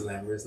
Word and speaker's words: Lambrus: XOXO Lammy, it's spelled XOXO, Casa Girl, L-A-M-M-Y. And my name is Lambrus, not Lammy. Some people who've never met me think it's Lammy.